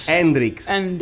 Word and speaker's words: Hendrix: Hendrix.